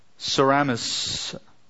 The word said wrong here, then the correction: Semiramis: Semiramis, I mispronounced her name, Semiramis claimed that she was immaculately conceived and that her son Tammuz was the result.